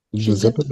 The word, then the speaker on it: Giuseppe: Also den Blumenstrauß muss man Giuseppe Verdi, äh, Giuseppe Verdi, wie heißt, wie wird er richtig ausgesprochen? Giuseppe, oder? – Giuseppe, Verdi, ja.